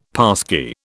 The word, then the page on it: passkey: British English